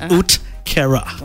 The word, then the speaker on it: uhtceare: It's spelled U-H-T-C-E-A-R-E. But it's spelled it's pronounced Utkera.